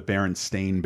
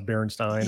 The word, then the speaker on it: Berenstain: Kareem Abdul-Jabbar accurately pronouncing the Berenstain Bears, oh, which none of us did. I, I have read about that so much with the Berenstain versus uh, Berenstein.